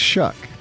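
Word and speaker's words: Shough: It looks like Shuff. – I know, and I screwed up. – Shuck. – It's Shuck. – Thank you. – Just think, oh, when he throws a pick, oh, Shuck!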